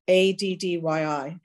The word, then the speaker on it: Addyi: ADDYI.